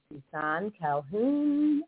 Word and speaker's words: Suson-Calhoun: This is Jodi Suson-Calhoun. Okay, I didn't do that right, because when my soul sisters from the other versions of the show, when they pronounce my name, they go, Reverend Jodi Suson-Calhoun.